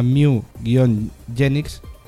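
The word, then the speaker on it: Mewgenics: Se llama Mew-Genix. Se escribe Genix con G. Y Mew como el Pokémon.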